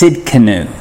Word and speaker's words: Tsidkenu: T, as in Tommy, S-I-D-K-E-N-U. So it's Tsidkenu. I know, it's like knife. What does yours say? – Just Tzedek. – Yep. – T-S-E-D-E-Q.